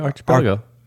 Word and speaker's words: archipelago: Archipelago? – Pretty good, yeah. Archipelago. – Say it again. – Archipelago? – Well, they told me don't say CH.